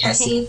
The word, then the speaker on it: Hesse: Monica Hesse, I think that's how you pronounce her name. Is that right, Hesse? – I think so. – Yes.